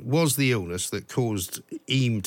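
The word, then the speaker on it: Eyam: But the first thing I learned was how to pronounce it, because some people pronounce it Iam and some people pronounce it Eam. So I'm told Eam is correct. Is that right? Locals pronounce it Eam. – Oh, Eam, OK. So the Y is kind of silent. – Pardon? – The Y is a sort of silent Y.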